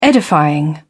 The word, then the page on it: edifying: British English